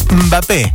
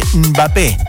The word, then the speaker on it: Mbappé: En vez de Mbappé, sacale la E, Mbappé, ¿sí? Es así, Mbappé, con la lengua en el paladar.